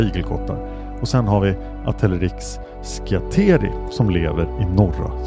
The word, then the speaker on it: Atelerix: Ett släkte är Atelerexis. Förekommer huvudsakligen i Afrika. Och i nära släkt med arterna i Erinaceus. Bland Atelerix, så uttalas det, förekommer afrikansk pygmeigelkott.